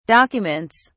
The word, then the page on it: documents: British English